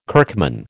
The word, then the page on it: kirkman: American English